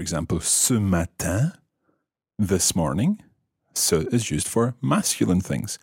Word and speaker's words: ce: It's spelled C E. Ce.